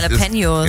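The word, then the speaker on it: Jalapeños: Und Japalenos, wie wird das immer richtig ausgesprochen? – Jalapenos.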